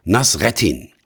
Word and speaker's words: Nasreddin: Der Hauptdarsteller dieser Geschichte heißt Nasruddin. Meine türkischen und arabischen Freunde sprechen den Namen allerdings anders aus. Nasreddin, Nasruddin Hoca oder ähnlich.